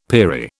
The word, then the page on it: peri: British English